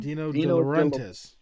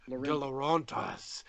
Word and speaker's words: De Laurentiis: Dino De Laurentiis. – Laurentiis, yeah. I don't know why De Laurentiis, over dinner, who was also producing Stephen King's Maximum Overdrive, that he should have his production company finance Evil Dead 2.